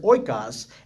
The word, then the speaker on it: oikos: So the word is called oikos. So oikos, if you look at that, those are Greek letters, but they look a lot like O-I-K-O-S, and they're pronounced very similar.